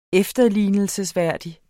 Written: efterlignelsesværdig

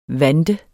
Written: VANTERNES

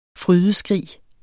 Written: frydeskrig

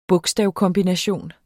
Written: bogstavkombination

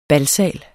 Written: balsal

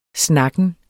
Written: snakken

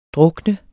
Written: drukne

